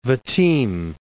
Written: Vitim

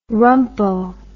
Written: rumpo

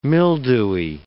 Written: mildewy

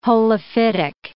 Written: holophytic

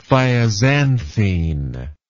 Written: thioxanthene

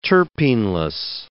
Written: terpeneless

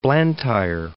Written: Blantyre

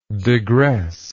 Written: digressingly

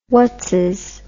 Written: whatzis

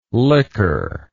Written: likker

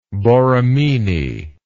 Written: Borromini

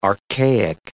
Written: archaic

